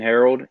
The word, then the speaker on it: Herald: Yeah, so you can follow me on Twitter at Jordan Herald, and my last name is spelled H-E-R-A-L-D, and then 5 at the end of it.